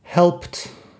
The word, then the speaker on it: helped: Helped es el pasado, la pronunciación como si fuera una T al final.